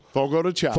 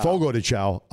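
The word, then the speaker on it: Fogo de Chão: Jay, an alternative to Fogo de Chao. Is that how you pronounce it? – Fogo de Chao. – Fogo de Chao.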